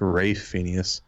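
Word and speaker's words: Ralph Fiennes: How do you feel like the combat with, I think his name's actually pronounced Rafe Phineas.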